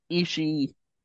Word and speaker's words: Ishii: It's Ishii. That's how you pronounce it.